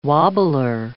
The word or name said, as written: wobbler